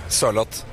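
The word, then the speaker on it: Sørloth: How do you say that? – A bit slower. Sorlot.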